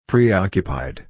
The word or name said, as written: preoccupied